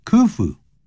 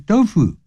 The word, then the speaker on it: Khufu: W-A-N-D-E-R-I-N-G wandering in that garden every morning 每天早上这样比较好一点免得吓人这样 Alright 我们接着再往下看 The Egyptian ruler 埃及的这个领导者也就是这个国王啦 Khufu 这个人但是千万不要念成 Dofu 那就不对了 Khufu ordered the construction of the pyramid.